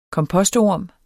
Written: kompostorm

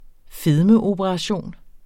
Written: fedmeoperation

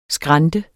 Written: skrante

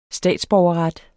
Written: statsborgerret

